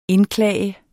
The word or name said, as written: indklage